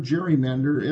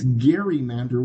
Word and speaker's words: gerrymander: Gerrymander, gerrymander, gerrymander. Every time it's spoken wrong. It's gerrymander with a hard G. Gerrymander, not gerrymander.